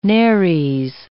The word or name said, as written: nares